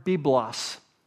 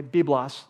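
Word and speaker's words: biblos (βίβλος): The first word is the word biblos. Okay, biblos. So it's spelled like this, B-I-B-L-O-S. B-I-B-L-O-S, biblos, all right?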